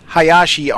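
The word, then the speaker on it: Hayashi (林): Number 9, Kobayashi, meaning small forest, using the kanji small and woods and the kanji for woods and forest. Pronounced Hayashi on its own.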